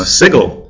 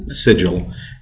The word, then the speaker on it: Sigil: We, we pronounced it Sigil, and we actually got some very irate people saying you should pronounce it correctly.